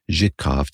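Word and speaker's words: Żytkow: And, you know, I gave it to them in writing and it's sort of a strangely written name because it's got a Z with a dot on top of it. Y-T-K-O-W. But it's pronounced Zhitkov to my best understanding.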